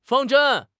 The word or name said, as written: 方针